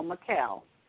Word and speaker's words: Mykal: M-Y-K-A-L. Mikkel.